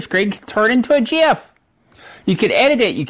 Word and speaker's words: GIF: The creator of the GIF, graphic interchange format, said that he pronounces it GIF, just like the peanut butter.